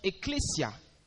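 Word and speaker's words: ecclesia (ἐκκλησία): Rentrez dans le grec et vous allez trouver que c'est un mot grec que l'on prononce ecclesia.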